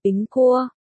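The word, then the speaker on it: bính cua (苹果): Đêm bình an, tặng quả táo, trong tiếng Trung, quả táo được phát âm là bính cua. Đồng âm với chữ bình trong từ đêm bình an, bình gần nhè, vì thế, người Trung Quốc đã nghĩ ra cách gửi tặng nhau những quả táo.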